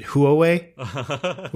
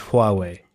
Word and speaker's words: Huawei: Which is like, I think in the very first episode we talked about them and I pronounce it Huawei, which is wrong, but it's Huawei.